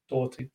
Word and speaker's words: Doherty: Clearly, Matt Doherty, Doherty. I watched a video the other day of him actually pronouncing his own name. Doherty.